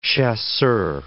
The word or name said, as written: chasseur